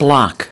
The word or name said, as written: Block